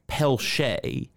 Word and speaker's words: Peltier: I get everything. I got Peltier. There's this one group I remember out in Texas. It's like Peltier. – Ooh. – It's spelled the same way as my name, but they have Shay in it.